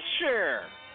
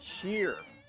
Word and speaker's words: Cher: They pronounce it like it's S-H-A-R-E. You ever hear people say Cher? – It is pronounced Cher. – It's Cher. – S-H-A-R-E. – Cher. – Out of here. – Cheryl. Cher. Cheryl. Cher. Not Cher. I used to work with a guy who pronounced it Cheer.